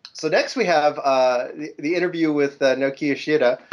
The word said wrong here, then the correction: Naoki Yoshida: I totally butchered it. Nokia what? – He's like, Shida. – Naoki Yoshida.